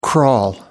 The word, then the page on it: crawl: American English